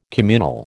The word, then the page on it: communal: American English